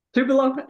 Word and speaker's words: Tupelo: We're not like a, you know, it's, so let's say we were, and I'm just using Tulupo because, and I, did I pronounce the name right? – Tupelo. – Tupelo. I knew I had it off a little.